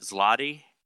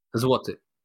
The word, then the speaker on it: zloty: Z-L-O-T-Y. – Yeah, it's like golden. – How do I pronounce that? Zloty? – Zloty.